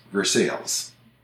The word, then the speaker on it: Versailles: But there are cities by that name in Illinois, Kentucky, Ohio, and Pennsylvania, and they're all pronounced Versailles. – Mm-hmm. Which is how you, once again, how you want to pronounce it when you read those letters. If you didn't know it was a French word, that's how you might pronounce it.